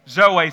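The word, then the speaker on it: Zoe (ζωή): And that word life in the Greek is the word zoe. Why don't you say that out loud with me? Zoe. Zoe. So we would pronounce it Zoe. Some of you, maybe you've named your daughter Zoe. It's that same word, Z-O-E.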